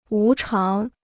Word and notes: 无偿: wú cháng

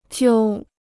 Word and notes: 揪: jiū

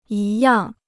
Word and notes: 一样: yī yàng

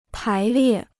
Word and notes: 排列: pái liè

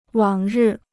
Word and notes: 往日: wǎng rì

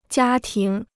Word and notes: 家庭: jiā tíng

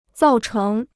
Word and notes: 造成: zào chéng

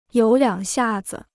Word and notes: 有两下子: yǒu liǎng xià zi

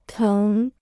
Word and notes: 疼: téng

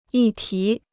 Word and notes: 议题: yì tí

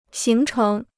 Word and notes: 形成: xíng chéng

